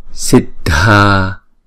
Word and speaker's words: Siddharthe: Chữ D và chữ H ghép lại thì chúng ta đọc là dh, dh, Siddhi, Siddharthe. Thấy không? Chữ A này nè là chúng ta sẽ đọc kéo dài hai nhịp. Siddharthe. Chữ này không phải là ra the mà là r, r, chữ R mà chúng ta chỉ đọc, chỉ đánh lưỡi lên thôi.